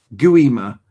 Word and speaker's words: Gwima: Gwima. Gu-i-ma is how it's saying. The gui sounds like gu-i, similar to the word we with a hard G.